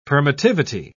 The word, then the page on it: permittivity: British English